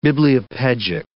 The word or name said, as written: bibliopegic